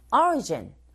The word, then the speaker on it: origin: Origin, origin 앞부분에 광세를 주면 되고 potatoes,